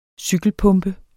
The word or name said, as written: cykelpumpe